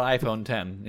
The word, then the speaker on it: iPhone X: The iPhone X, which is supposed to be called the iPhone 10, is how you're supposed to pronounce it, I guess.